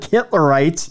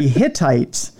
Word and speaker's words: Hittites: I'm going to pronounce this very slowly so it doesn't sound like Hitlerites, but it's the Hittites.